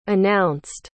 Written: announced

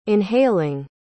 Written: inhaling

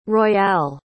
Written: royale

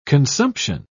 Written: consumption

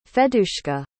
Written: Fadeyushka

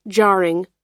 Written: jarring

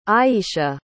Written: ayisha

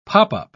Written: pop-up

